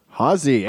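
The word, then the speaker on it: Hazzeh: Hosse? Hosse. Did I say it right? – Hosse. H-A-double-Z-E-H. – What's up, Zach? – Not a whole lot. Sounds nothing like it's spelled, but here we are.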